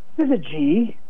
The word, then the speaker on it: syzygy: I think a very fun one is syzygy. Do you know this word? – Syzygy? No. – It is a great Scrabble word, and it's spelled S-Y-Z-Y-G-Y, pronounced syzygy.